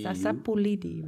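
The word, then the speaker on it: sapu lidi: Sapulidi, right? – Space and LIDI.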